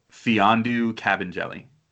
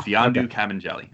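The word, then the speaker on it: Mfiondu Kabengele: They have the pronunciation guide on Basketball Reference. – I was going to say, I don't have to say his name all the time. – Fiondu Cabanjelli. I would not have guessed that for his first name, but wow. Fiondu Cabanjelli. I got it right this time now.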